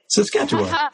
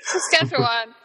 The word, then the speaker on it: Saskatchewan: Saskatchewan. – Saskatchewan.